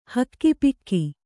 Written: ಹಕ್ಕಿಪಿಕ್ಕಿ

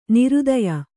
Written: ನೃತ್ಯ

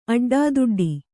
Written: ಅಡ್ಡದುಡ್ಡು

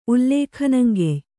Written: ಉಲ್ಲೇಖನಂಗೆಯ್

